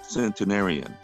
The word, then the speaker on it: centenarian: Why do I sound like a centurion? We're moving up there. Wow. – I know. It's a lot of episodes. It's a lot. – Let me do the pronunciation. Centenarian.